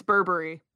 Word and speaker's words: Burberry: Pronouncing it wrong, though. – I am? – It's Burberry. – Burberry. – Yeah, Burberry. – So I spelled it correctly, but said it wrong.